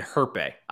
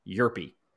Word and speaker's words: Yerpe: I've been saying Herpe. I was trying way too hard. It's Cooper Yerpe.